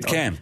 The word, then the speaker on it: Pitcairn: And it's a UNESCO World Heritage Site in the Pitcairn group of islands. I think that I'm saying that right. – Pitcairn.